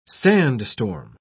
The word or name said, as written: sandstorm